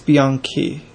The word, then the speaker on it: Bianchi: I think it's Bianchi. – Okay. All right. – It's a C-H-I, but C-H... – Is that like Chianti? – Yeah.